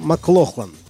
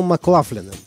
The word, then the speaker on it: McLaughlin: В начале 60-х, когда молодой гитарист переехал в Лондон, его фамилию все еще произносили по-шотландски – Джон Маклохлан. Но в 69 году он перебрался в Нью-Йорк. И с тех пор, как он сам с усмешкой говорит, стал Маклафлином. Так произносили его фамилию американцы.